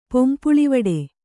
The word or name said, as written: ಪೊಂಪುಳಿವಡೆ